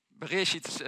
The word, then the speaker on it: Bereshit: Maar Bereshit spreek je het uit.